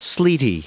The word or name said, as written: sleety